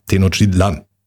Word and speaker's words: Tenochtitlan: Der Apostroph ist auf, oder der, die Betonung liegt auf dem letzten A. Tenochtitlan.